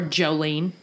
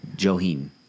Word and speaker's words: Johiehon: Just as far as names go for our Native American mother, it's spelled J-O-H-I-E-H-O-N. – Joheen? – Oh, my God. – Or Jolene. – Joheen.